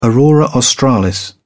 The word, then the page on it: aurora australis: British English